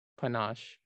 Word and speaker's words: panache: panache. Is that how you pronounce that word? – I believe so.